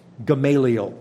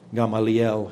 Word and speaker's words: Gamaliel: a man named Gamaliel. At least that's how we pronounce it. They probably say Gamaliel in, um, in Hebrew, but I screw up Hebrew every time I try.